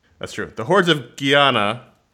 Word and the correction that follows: Gehenna: I can't pronounce it. The point is... – Guiana? – Gehenna. Oh. Gehenna. Gehenna. – Gehenna. Yeah, like the Gehenna Stone.